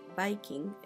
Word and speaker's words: Wiking: Em, el autor se llama Mike Viking, se escribe M-E-I-K y Viking es W-I-K-I-N-G.